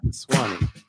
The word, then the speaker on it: Ramaswamy: I think it's Vivek Ramaswamy. – Ramaswamy? – Yeah. – Ramaswamy.